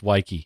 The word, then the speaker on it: wiki: It's supposed to be pronounced wiki, yeah. – Wiki. – W-I-K-I, wiki.